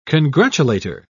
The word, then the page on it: congratulator: British English